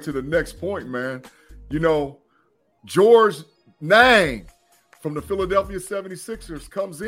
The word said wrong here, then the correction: Niang: How do you say his last name? – Look like it sound. Niang.